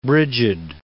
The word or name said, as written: Brigid